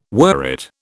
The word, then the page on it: worrit: British English